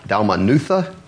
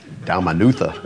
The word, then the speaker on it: Dalmanutha: So Jesus and his disciples in verse 10, it says they get into a boat and they leave for what is Dalmanutha. I don't think I said it right the first time, but you try saying that one. Dalmanutha, that's how it is.